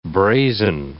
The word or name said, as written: brazen